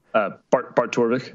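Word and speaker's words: Bart Torvik: Bartovic? How do you pronounce this again? – Bartorvik. – Bartorvik, pardon me.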